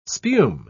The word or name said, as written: spume